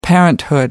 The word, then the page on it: parenthood: American English